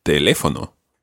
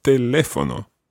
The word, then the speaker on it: teléfono: So if there was no accent on teléfono, it would be teléfono, but that's wrong. So it's teléfono.